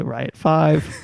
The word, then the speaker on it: Riot V: The V is a Roman numeral, so Riot 5.